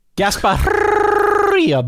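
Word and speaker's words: Gasparilla: The Union Home Mortgage, Gasparia Bowl, Dan. – I like it. – We go with the Spanish pronunciation here on the Solid Verbal.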